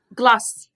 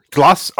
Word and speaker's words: glace: How how do you pronounce it? – Glass. Mm-hmm. – Glass. I got it right.